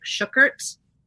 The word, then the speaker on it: Shukert: I think that like the actual German pronunciation of it is like Shukert. – Okay. – That's sort of how my grandfather would say it, but then it got more anglicized as time went on. – Okay, so we've all been pronouncing it wrong. – Yeah, we're fine. Like. Like, I don't want it to rhyme with, like, fuckert.